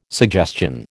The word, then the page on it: suggestion: American English